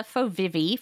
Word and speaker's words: FOVIVI: Together, they form an acronym, which I will allow you to pronounce. But can you tell us about the components of the social epistemic framework that that the book is organized around? – Yeah. So I call it the FOVIVI framework.